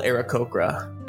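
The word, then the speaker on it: Aarakocra: So as our heroes enter this clearing and see this tiny, tiny, is it Arakocra? Is that how you say it? – Yeah, I guess I could have described that a little better. I'm sorry. Yeah, he's an owl Arakocra. A-A-R-A-K-O-C-R-A, I believe is the right spelling.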